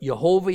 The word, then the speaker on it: Yehovah: is further evidence that God's name was spoken as three syllables, not two. Now, we can agree to disagree about it. But Yehovah is how you're going to usually hear it out of my mouth throughout this study of the book of Hosea.